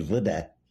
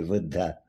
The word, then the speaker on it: Buddha: And it's interesting that if you think, I mean, one of the persons I admire most is Gautam Buddha, wrongly, uh, actually, wrongly pronounced in American or English as Buddha, uh, mm-hmm. V-U-D-D-H-A, Buddha.